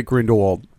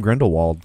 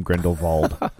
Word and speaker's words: Grindelwald: Only the muggles pronounce it Grindelwald. You, if you're a true magic person, you say it with a V. – Okay. – Okay, then I'm calling him Grindelwald.